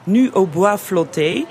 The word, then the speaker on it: Nu au bois flotté: Op een eh, foto die heet, moet ik even het netjes eh, Frans uitspreken, Nu au bois flotté.